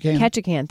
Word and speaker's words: Ketchikan: Ketchikan.